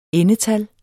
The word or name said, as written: endetal